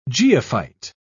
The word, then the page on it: geophyte: British English